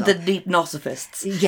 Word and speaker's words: Deipnosophists: back in the third century, Athenaeus wrote a book, which I can never pronounce the title The Deipnosophists. Yeah, that's right.